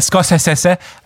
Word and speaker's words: Scorsese: Und die wenigsten wissen, dass der Scorsese äh, ausgesprochen wird.